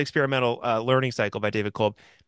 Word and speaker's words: Kolb: David Cobb or Kolb? Kolb? I've seen his name a million times and okay, great. Now I know.